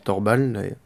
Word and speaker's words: torball: Alors, le torball, parce que ça se prononce torball d'abord,